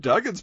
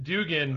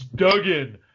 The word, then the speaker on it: Duggan: And like, the one time he addressed him, and Jeff's like, well, I think Hacksaw Jim Duggan's pretty cool. – Well, no, that's because I pronounced it Dugan.